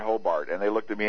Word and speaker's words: Hobart: It is pronounced Hobart, as it were an E in there.